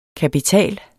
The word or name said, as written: kapital